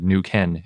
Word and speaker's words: Neuquén: And I think I've been pronouncing Neuquén wrong. It's spelled N-E-U-Q-U-E-N. – With an accent over the E. – Yeah, but I found a pronunciation guide that said Neuquén is how you say it.